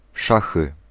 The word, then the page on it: szachy: [ˈʂaxɨ]